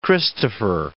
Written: Christopher

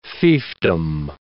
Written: fiefdom

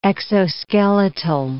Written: exoskeletal

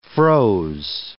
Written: froze

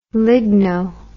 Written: ligno

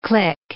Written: clique

